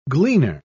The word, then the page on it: gleaner: British English